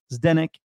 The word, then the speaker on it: Zdenek: Zdenek is my name. It's spelled Z-D-E-N-E-K.